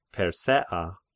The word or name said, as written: Persea